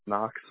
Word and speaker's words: Knox: It's first name is going to be N-O-X, and then Logan, K-N-O-X. – Obviously. Oh, what's the first? – K-N-O-X. – K-N-O-X. Okay. – So not K-N-O-X. It's a silent K.